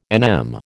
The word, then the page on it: NM: American English